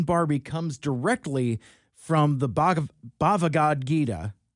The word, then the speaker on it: Bhagavad Gita: Bhagavad Gita, often referred to as simply the Gita.